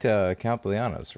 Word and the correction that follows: Castellanos: right? Or however you say his name. They kept him, right? – Castellanos.